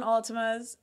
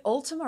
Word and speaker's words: Altima: Yes, Altima. Absolutely. – Nissan Altimas. Is it Ultima or Altima? Altima. – I'll say Altimas. – Yeah, it's Altima.